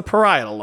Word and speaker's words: parietal: I asked for the correct pronunciation of that from my fiance who works uh, who works in brain science, and it's the parietal lobe.